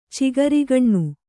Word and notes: ಚಿಗರೆಗಣ್ಣು: cigaregaṇṇu

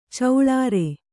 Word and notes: ಚಿಲ್ಲರೆ: kannada

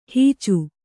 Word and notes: ಹುಚ್ಚ: Kannada

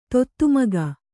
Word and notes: ದತ್ತುಮಗ: dattu maga

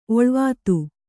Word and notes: ಒಳುವಾತು: kannada

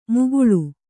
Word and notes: ಮುಗುಳು: muguḷu

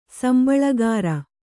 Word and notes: ಸಂಬಳಗಾರ: sambaḷagāra; Kannada

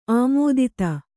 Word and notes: ಆಮೋದಿತ: kannada

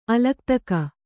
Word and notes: ಅಲಕ್ತಕ: alaktaka

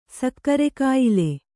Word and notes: ಸಕ್ಕರೆಕಾಯಿಲೆ: sakkare kāyile

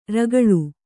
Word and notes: ರೌಕುಳಿ: raukaḷi

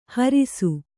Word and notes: ಹರುಸ: Kannada

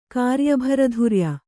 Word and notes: ಕಾರ್ಯಭರಧುರ್ಯ: kāryabharadhurya

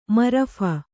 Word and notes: ಮರಫ: marapha

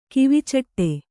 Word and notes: ಕಿವಿಚಾಟು: Kannada

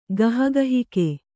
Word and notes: ಗಹಗಹಿಕೆ: gahagahike

